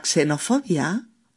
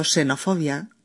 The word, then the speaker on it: xenofobia: La xenofobia o xenofobia, que también se pronuncia así como si fuera una S.